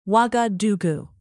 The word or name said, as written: ouagadougou